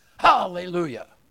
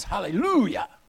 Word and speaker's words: hallelujah: We could correctly have it as hallelujah. Is that good? Hallelujah. Or just as properly as hallelujah. Hallelujah. All right, you catch on. Now, the final understanding, the final proper pronunciation could be hallelujah. Why? Well, because Yah is short for Jehovah, the word for God himself.